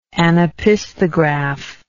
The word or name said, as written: anopisthographically